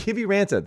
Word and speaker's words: Kiviranta: Kiviranta.